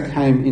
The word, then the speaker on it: Ham: Now, perhaps the penny hasn't dropped yet, but the proper pronunciation of Ham is Ham. Okay, it's a K sound. And we just pronounce it Ham because it's easier to pronounce in English. But in Hebrew, it's pronounced Cam.